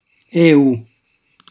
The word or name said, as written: eu